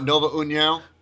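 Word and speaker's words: Nova União: Well, I think this might be another case of uh, of uh, Nuva. How do you say that? Where they train? Nuva oh, Nova, Nova Uniao.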